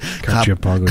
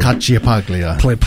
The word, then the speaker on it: Cacciapaglia: Cacciapaglia. – Cacciapaglia.